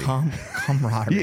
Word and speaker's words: camaraderie: How do you say it? – It's camaraderie. – Camaraderie. – You had me screwed up.